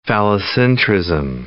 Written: phallocentrism

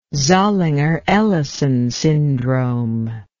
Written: zollinger ellison syndrome

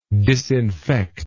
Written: disinfector

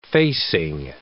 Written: facing

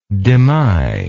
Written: demy

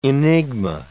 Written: enigma

